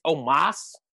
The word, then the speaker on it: Omos: It's Omos.